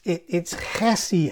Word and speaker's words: chesed: Now, I'm told that if you're not spitting on somebody or something when you say this word, you're pronouncing it wrong, because it's chesed, chesed, chesed.